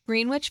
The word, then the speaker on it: Greenwich: Soho, Times Square, Greenwich Village, or whatever is the most convenient to our schedule? – Probably Greenwich. – Greenwich? – That's how you say it. – What? – Greenwich Village.